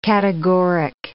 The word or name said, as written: categoric